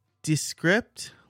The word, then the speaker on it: Descript: So I'm going to say Descript, like description, description.